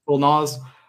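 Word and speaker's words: Lil Nas: I think, like, Little Nas, is that how you say his name? – Little Nas.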